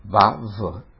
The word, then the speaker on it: Vav: Uguale per la Vav. Vav, la Yud praticamente salta.